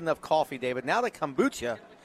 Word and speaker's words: kombucha: But that kombucha.